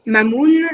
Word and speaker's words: Mamoon: Mamoun, ça s'écrit comme la lune, M A M O O N by Très bien.